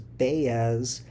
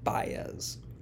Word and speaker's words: Baez: In the original miniseries, I mispronounced his name as Baez until a friendly listener eventually pointed out my mistake and corrected me. For the record, it is pronounced Baez.